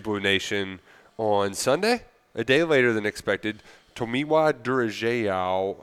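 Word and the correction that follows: Tomiwa Durojaiye: Oh, man, I already – Tomiwa Durajayi, I think it is. – There we go. Yeah, wow. That was a great Oklahoma pronunciation. – Tomiwa Durajayi. – Yeah, that was good.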